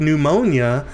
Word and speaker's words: pneumonia: So we see, yes, we see in a translation, in an English word like pneumonia, that P is silent.